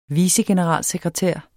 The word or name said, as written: vicegeneralsekretær